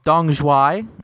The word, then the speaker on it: Dong Xoai: We are here to talk about your new book, Dong Xoai, and I believe I pronounced that right. – Yes, you did.